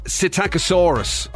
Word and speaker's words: Psittacosaurus: Apparently the correct pronunciation for that dinosaur is Psittacosaurus. Yeah, so you don't pronounce the P. – Oh, oh, okay, right, so yeah. The P is silent.